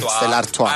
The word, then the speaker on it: Stella Artois: Stella Artois. – Mm. Stella Artois.